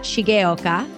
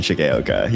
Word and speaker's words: Shigeoka: I am delighted to introduce my guest, Scott Shigeoka. Did I say that right? – You did. I put the gay in Shigeoka. Yeah, I put the gay in Shigeoka. Yeah, Shigeoka. There you go.